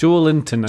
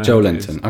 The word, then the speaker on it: Joelinton: Brazilians pronounce his name Joelinton. Someone should get in touch and tell us if we're right about that. – It's Joelinton. – Joelinton. Okay, fine.